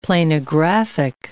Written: planographic

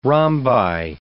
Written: rhombi

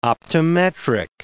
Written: optometric

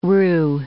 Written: rue

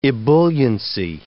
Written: ebulliency

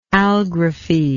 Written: algraphic

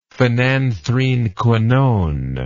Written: phenanthrenequinone

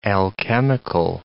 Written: alchemical

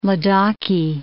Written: Ladakhi

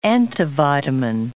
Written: antivitamin

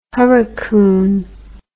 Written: hurricoon

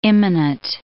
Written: immanent

